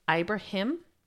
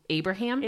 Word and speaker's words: Ibrahim: The correct pronunciation, which we had to actually look up, is Ibrahim. But our brains automatically corrected it to Abraham.